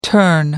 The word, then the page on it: turn down: American English